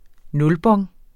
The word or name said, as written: nulbon